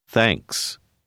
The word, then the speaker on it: thanks: Thanks. Thanks. – Remarquez qu'il arrive que les lettres TH sonnent comme si elles étaient soufflées.